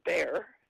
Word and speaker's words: Bexar: And then I need to say that San Antonio was known as Bejar de San Antonio, and Bejar, B-E-X-A-R, is now pronounced as bear, because Americans find it hard to say huh.